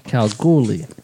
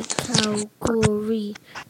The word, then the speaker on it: Kalgoorlie: Kalgoorlie. So he's kind of joining us. – It's kind of some people say Calgary. You'll see some people saying Calgary.